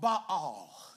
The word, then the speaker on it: Baal: They would worship the god of Baal. That's how you say it in real Hebrew.